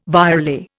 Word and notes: virelay: Indian

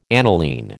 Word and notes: aniline: American English

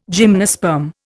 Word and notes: gymnosperm: Indian

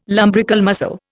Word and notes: lumbrical muscle: Indian

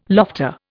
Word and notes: lofter: Indian

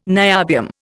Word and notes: niobium: Indian

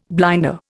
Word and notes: blinder: Indian

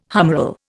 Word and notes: humeral: Indian

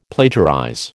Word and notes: plagiarize: American English

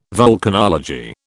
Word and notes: volcanology: British English

